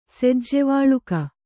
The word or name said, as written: ಸೆಜ್ಜೆವಳಿಗೆ